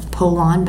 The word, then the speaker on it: Pollan: And that, along with other work from like Michael Pollan, I think his name is, it looks like Pollan, but he pronounces it Pollan.